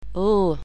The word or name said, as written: l